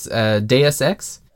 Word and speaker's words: Deus Ex: And in the second matchup, we had Bravely Default versus Deus Ex.